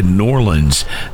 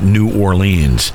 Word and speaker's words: New Orleans: That's how they say it, New Orleans, not New Orleans.